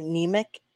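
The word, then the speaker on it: Nemec: Simon Niemek, right? That's how you say it. Niemek.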